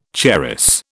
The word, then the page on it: charas: British English